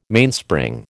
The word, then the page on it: Mainspring: American English